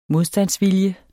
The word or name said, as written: modstandsvilje